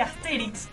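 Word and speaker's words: Astérix: Dice, lo importante es que Asterix, con acento en la E, se vuelva a publicar en Argentina.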